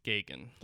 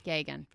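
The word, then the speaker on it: Geoghan: Because it's G-E-O-G-H-A-N, but it's Gagan. – Gagan.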